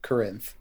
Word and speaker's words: Corinth: It is Corinth. – Some of the locals would say that Corinth is the original way to say it.